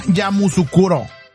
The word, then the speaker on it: Yamoussoukro: ¿Cómo se llama? – Yamoussoukro. – ¿Yamoussoukro? ¿Yamoussoukro? – Ajá. La se pronuncia.